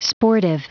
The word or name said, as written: sportive